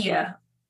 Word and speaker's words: Bahia: And is it the Bahia? I want to make sure I pronounce it correctly in Portuguese. Bahia. – Bahia. Bahia. – Yes. Right. – Bahia. Yeah. Okay. It would be similar to Spanish.